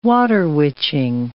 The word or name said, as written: water witching